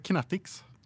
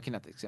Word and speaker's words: Kinetics: Och sen har vi det vanliga då som är, uttalar sig Kinetics. – Ja, Kinetics.